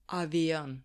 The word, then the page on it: avion: Serbian